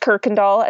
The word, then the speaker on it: Kuykendall: It is K-U-Y-K-E-N-D-A-L-L. – Yeah. And pronounce that again. – It's pronounced Kirkendall as if there's an R, but there is no R.